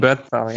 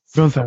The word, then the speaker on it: Bernthal: dem Schauspieler Bernthal wird er ausgesprochen, ja? – Mhm. – John Bernthal, ja. – Bernthal. Vermutlich, ja.